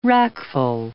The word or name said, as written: wrackful